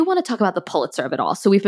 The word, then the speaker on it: Pulitzer: Also thrilled to know after reading this book, because there is a Pulitzer winning author in this book, that according to Andrew Sean Greer, it is pronounced Pulitzer.